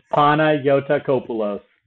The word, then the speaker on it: Panagiotakopoulos: It's Panayotakopoulos.